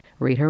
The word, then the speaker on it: Rita: Rita Rochelle is my name, of course, and I'll spell it for you. Rita is R-I-T-A.